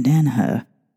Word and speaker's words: Danaher: then you probably have heard the name John Danaha. Sorry, I don't know. I have to say his name